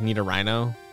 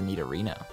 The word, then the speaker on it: Nidorino: I think I would have pronounced that as a Nidorino. – See, that's what, because I forget how I said it at first, but yeah. It is rhino without the H.